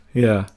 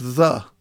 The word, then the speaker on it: ye: Yeah. – Do you want a fascinating thing about the phrase ye? – Go on. – It's actually pronounced the.